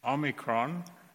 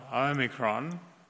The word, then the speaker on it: Omicron: Micron means small, and hence Omicron, not Omicron.